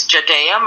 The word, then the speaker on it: Jodaea: Okay, well, like you said, my name is Jodaea Minor White.